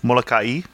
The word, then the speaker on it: Molokai: You're supposed to pronounce every vowel, right? So it's Molokai? – That sounds right to me.